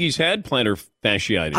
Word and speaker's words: fasciitis: Now, Tiger Woods is out of the Hero Open or some big event with plantar fasciitis. I'm told it's pronounced not fasciitis, fasciitis.